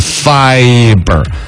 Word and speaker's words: fiber: 今天节目里头说那个 separate 的那个重音是在发上头，还有之前那个典型的广告那个 fiber to room 那个 fiber 发音应该是 fiber 啊，王黑石天天听你读，有时候呢就开始怀疑自己了。